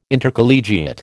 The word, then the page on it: Intercollegiate: American English